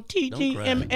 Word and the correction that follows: TJMS: I was saying it wrong. The TJMS show.